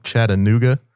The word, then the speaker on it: Chattanooga: Katie's coming to us all the way from Chattanooga, Tennessee.